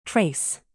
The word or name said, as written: trace